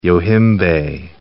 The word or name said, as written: yohimbe